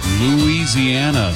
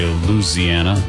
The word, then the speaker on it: Louisiana: a Bob Classic, Mel McDaniel, Louisiana, Saturday night. Or you can drop one of the syllables and just say Louisiana, Louisiana.